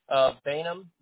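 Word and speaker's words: Baynum: Yep, Z-A-C-H-A-R-Y. – Awesome. And last name, sir? – Bainum, that's spelled B-A-Y, and then... N-U-M, like your mouth is numb or something like that. Just drop the B.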